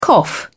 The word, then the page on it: cough: British English